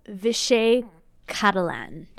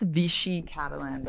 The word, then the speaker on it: Vichy Catalan: Okay, next we have Vichy Catalan. Did I pronounce that correctly? – I think it's Vichy Catalan, but we can Vichy Catalan.